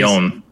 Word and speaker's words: Lyon: It's coming from Arkane, but it's coming from Arkane Lion. – Arkane Lyon. – Lyon, right. Yeah, I did mispronounce that.